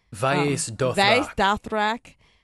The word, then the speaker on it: Vaes Dothrak: We were introduced to, they finally arrive at the Vaes Dothrak, if I'm saying that correctly. – Vaes Dothrak.